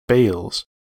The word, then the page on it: bales: American English